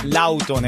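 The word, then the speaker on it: Lawton: L-A-W. – Lautown es. Decir en inglés, Lautown. Yo en Santo Suárez, cerca de Lautown. – Lautown, ¿cómo se pronuncia? – Lautown. – Lautown, ah, ok.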